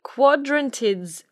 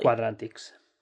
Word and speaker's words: Quadrantids: So January 3rd and 4th, we have the Quadrantids meteor shower. I can never say that correctly. – Quadrantids. – Quadrantids, yes, meteor shower.